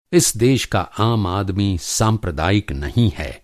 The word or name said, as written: सांप्रदायिक